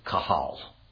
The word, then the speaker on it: qahal: The first word is the word kahal. You would spell it Q-A-H-A-L.